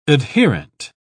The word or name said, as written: adherent